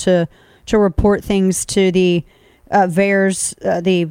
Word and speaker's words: VAERS: Is it VAERS for short? – Yeah.